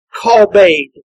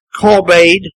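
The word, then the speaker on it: Kalbed (כָּבוֹד): Here too is a curious word in the Hebrew. That word we translate glory. Kalbed. Strong would tell us is the pronunciation of this word. Kalbed.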